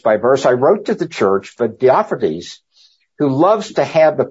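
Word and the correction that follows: Diotrephes: So, and this guy, Diotrophes, I guess better pronunciation, he was apparently an elder or a self-appointed leader of an unnamed assembly.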